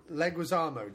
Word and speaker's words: Leguizamo: Leguizamo, John Leguizamo. – Leguizamo, yeah.